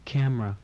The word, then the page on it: camera: English